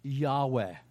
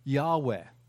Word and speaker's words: Yahweh: He is Yahweh. That's our pronunciation. Written out, it's YHWH. Or YWW. Let me get that right. YHWH. That's right. I got it right. Yahweh.